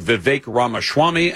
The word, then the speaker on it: Vivek Ramaswamy: There isn't one of those other Republicans running, including Ron DeSantis, including Vivek Ramaswamy. And actually, I can say his name correctly. Many of the people on TV do not.